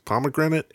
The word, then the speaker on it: pomegranate: It rhymes with immigrant, pomegranate, immigrant, whatever.